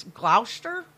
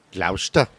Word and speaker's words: Gloucester: It's Gloucester. – Gloucester.